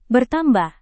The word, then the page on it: bertambah: indonesian